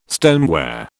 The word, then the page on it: stoneware: British English